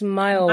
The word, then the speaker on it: Myoa: I guess it's Myoa, like Iowa from um, my original name, Mayowa.